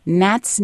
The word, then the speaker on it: gnat: The first is called 99 Gnats, Nits, and Nibblers. So Gnat, of course, starts with a G, but it's a silent G.